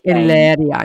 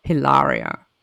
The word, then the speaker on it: Hilaria: And it's pronounced Hilaria, not Hilaria.